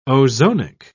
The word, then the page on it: ozonic: British English